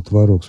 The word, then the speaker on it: творог: Что касается ее замечания о том, что уже рекомендуется произносить слово «творог» с ударением на первом слоге, я хочу подчеркнуть, что и 40, и 50 лет назад это слово в орфоэпических словарях подавалось как вариантное с двумя допустимыми вариантами произношения.